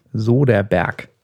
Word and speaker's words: Soderbergh: Wie spricht man das aus? Keine Ahnung, wie schreibt man das denn? Soderbergh. Ja, spricht man das auch so aus.